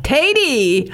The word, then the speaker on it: Tady: So is it pronounced Taddy? Tady? – It's actually Tady. Rhymes with lady, yes. – Tady.